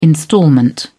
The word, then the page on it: instalment: British English